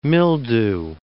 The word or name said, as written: mildew